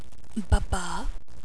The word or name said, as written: Bubba